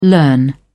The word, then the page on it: learn: British English